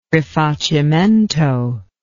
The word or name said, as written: rifacimento